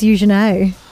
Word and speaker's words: Eugenie: Now, Princess Eugenie, as I've found out her name is pronounced. – Really? – It is. This whole time, I've just thought it was Eugenie. – Eugenie, yeah. – It's apparently. It's Eugenie. It's like when people say Tajay, I think.